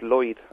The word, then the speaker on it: Lloyd: Es que la pronunciación del nombre de ese hombre es Lloyd, aunque parece ella en español.